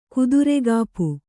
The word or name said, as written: ಕುದುರೆಗಾಪು